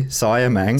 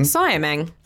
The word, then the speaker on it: Siamang: Siamang. – Siamang.